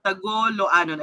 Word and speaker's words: Tagoloanon: Tagolaoanon. I'm sorry, tama sana ang pronunciation ko. Tagoloanon.